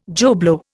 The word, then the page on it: Joe Blow: Indian